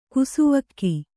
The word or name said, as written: ಕಿಸುವಲಗೆ